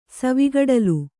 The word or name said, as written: ಸವಿಗಡಲು